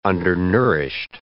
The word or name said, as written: undernourished